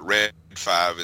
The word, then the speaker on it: Read 5: Like he said, we're pronouncing it Red 5. It's R-E-A-D 5, or the, you know, F-I-V-E, Read 5, Red 5, in the UK.